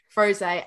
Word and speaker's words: Frosé: And this one is pronounced, for all I can tell, Frosé.